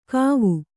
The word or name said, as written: ಕವಿ